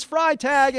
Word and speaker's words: Freitag: But her last name is Freitag, and she is correct. Freitag rhymes with fries.